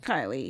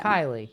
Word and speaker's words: Kylie: And like, yeah, we talked about toe sucking, but like, I just have so much in common with Kaylee or Kylie. I don't remember how you pronounce her name. – Kylie, yeah. – Kylie, yes. Yeah.